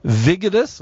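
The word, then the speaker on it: Vigdís: I, am going to say that again because that's how it's Vigidus, V-I-G-D-I-S, Finnbogadottir.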